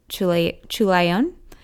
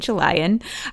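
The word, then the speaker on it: Chalayan: Antonio Berardi and Hussein, I can never say his last name right, Chulayan. There we go. I got it. Hussein Chulayan. I wrote it out phonetically.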